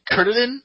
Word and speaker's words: Kuririn: You know, Krillin, the Japanese, his actual Japanese name is, I'm trying to pronounce it right, Kurtadin, is spelled, K-U-R-I-R-I-N.